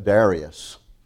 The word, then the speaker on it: Darius: It says, in the first year of Darius. Some people pronounce that Darius. All right, so it just depends on who you're listening to. I've gotten used to Darius, so I'm going to stay with it because I'll fumble if I don't stay with what I think is about right.